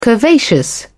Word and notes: curvaceous: British English

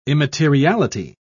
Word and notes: immateriality: British English

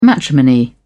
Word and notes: matrimony: British English

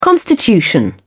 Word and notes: constitution: British English

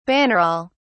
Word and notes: bannerol: British English

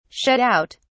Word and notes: shut out: British English

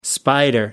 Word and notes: spider: American English

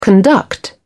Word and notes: conduct: British English

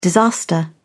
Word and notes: disaster: British English

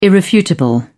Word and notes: irrefutable: British English

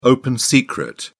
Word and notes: open secret: British English

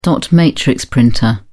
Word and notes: dot matrix printer: British English

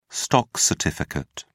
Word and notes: stock certificate: British English

